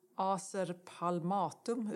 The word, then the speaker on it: Acer palmatum: I alla fall såg en vacker japansk lönn, Acer palmatum, uttalar man det så, tror jag. – Ja, det är rätt.